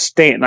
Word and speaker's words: Staunton: Once I learned that Staunton is pronounced Stanton, I shut up and just walked. I was like, it's got a U in it. Nay, it's pronounced Stanton. Like, what?